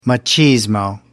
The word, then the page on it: machismo: American English